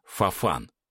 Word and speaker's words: Фафан: «Фафан». Ударение на «а».